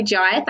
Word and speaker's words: Jai: Charley Jai. Thanks for saying it right. Because loads of people say Jay and it's not that. It's spelled J-A-I, so Jai.